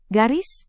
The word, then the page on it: garis: Indonesian